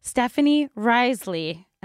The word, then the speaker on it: Stephanie Riseley: Today is a very exciting episode because we have in the studio Stephanie Riseley.